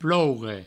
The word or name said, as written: ploure